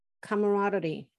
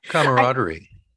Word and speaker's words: camaraderie: And I think you can also feel when people are joyful or are willing to work together and there's a good camaraderie. How do you say that word? – Camaraderie. – Thank you. – Camaraderie. Yes.